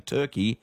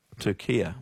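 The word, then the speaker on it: Türkiye: But in yesterday's Socceroos updates, you kept saying Turkey and not... – Turkia.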